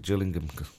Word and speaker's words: Gillingham: And the one down the road, in, you call it Gillingham, don't you? – Yeah. – I pronounce it Gillingham.